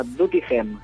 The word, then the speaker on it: Doetinchem: Sí, nos vamos a Holanda y bueno, ya me he aprendido a pronunciarlo, el pueblo se llama Duttingem. – Duttingem. – para nosotros es Doetinchem,